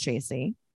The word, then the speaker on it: Tracey: I was triggered because my name is Tracy. – Yeah. – So my name is spelled T-R-A-C-E-Y, but, yeah.